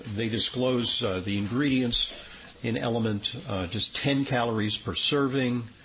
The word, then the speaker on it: LMNT: All right, there's a little bit of a controversy going on in nutrition land, and it's about a popular electrolyte supplement called LMNT, pronounced element. It's kind of clever, LMNT, element.